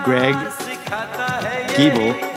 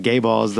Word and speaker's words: Giebel: His name is Greg Giebel. – We just learned how to pronounce it. – Sean was saying gay balls the whole time.